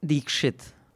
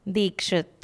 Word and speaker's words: Dixit: Dikshit. – Dixit, D-I-X-I-T. – Say Dikshit. – Dikshit.